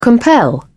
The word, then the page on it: compel: British English